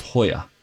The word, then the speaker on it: La Jolla: La Jolla, spelled J-O-L-L-A, is pronounced Hoya. – Right. Because the J is an H, yes.